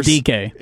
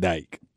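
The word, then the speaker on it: Dike: Okay, apparently I pronounced his name wrong. – It's DK. – They're saying it's DK. Chimeri DK, not Dyke.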